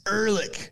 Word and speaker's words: Ehrlich: I hope I said your name right. – It's Ehrlich. – Ehrlich. Okay, like Ehrlich. Viking names.